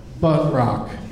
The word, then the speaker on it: Buntrock: B-U-N-D as in dog. – Uh-huh. – T-R-O-C-K. – B-U-N-D-T-R-O-U-C-K? – No, U. – O-C-K. Bunt Rock.